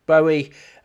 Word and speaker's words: Bowie: Cambo comes from Cambridge, obviously, not Cambo, as I said, it's pronounced like Rambo, and it's Bowie, not Bowie.